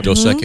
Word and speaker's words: Dos Equis: I like that Dos Equis. Is that how you say it? – Yeah, Equis? Yep. Dos Equis.